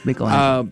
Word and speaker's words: Michelangelo: It's Michelangelo.